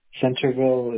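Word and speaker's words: Centreville: You said Anchorville? – Centerville. Centerville, it's spelled Centerville. – Like center, except instead of ER, it's RE, and then Ville.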